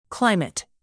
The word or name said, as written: climate